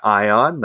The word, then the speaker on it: Ion: My name is Jeremiah Ion. That's I-O-N.